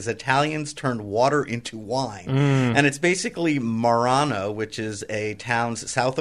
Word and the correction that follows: Marino: How do you spell it? – M-A-R-I-N-O. – Marino. – Marino, sorry.